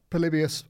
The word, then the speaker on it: Polybius: And obviously the Polybius heist, have I said that right? – You absolutely have.